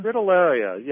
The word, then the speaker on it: Fritillaria: Fritillaria, yeah.